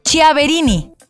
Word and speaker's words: Chiaverini: El primero es de Jennifer Chaverini, así se llama, Jennifer Chiaver, Chiaverini, así se escribe, Jennifer Chiaverini,